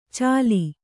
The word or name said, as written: ಛಲಿ